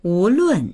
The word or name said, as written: wu2lun4